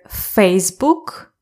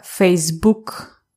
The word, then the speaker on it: Facebook: Facebook can have two stresses. So we can say either Facebook or Facebook. I say Facebook usually.